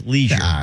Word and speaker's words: leisure: It doesn't sound right when you pronounce it like that. It's leisure.